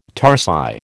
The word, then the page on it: tarsi: American English